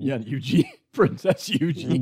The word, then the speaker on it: Eugenie: It's pronounced Eugenie, which is, I guess, an English way of pronouncing that.